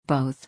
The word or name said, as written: both